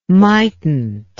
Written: mightnt

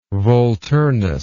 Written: volturnus

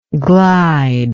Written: glidingly

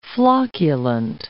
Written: flocculant

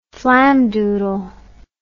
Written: flamdoodle

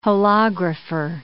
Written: holographer

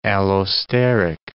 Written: allosteric

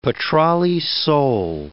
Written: petrale sole

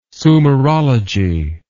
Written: sumerology

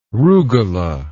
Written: rugula

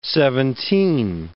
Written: seventeen

